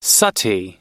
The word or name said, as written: sati